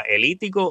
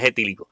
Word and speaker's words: etílico: Creo que yo a veces, hay, veces que eh, confundo la sílaba elítico o etílico, etílico, sí, es etílico, eh, disculpen esa.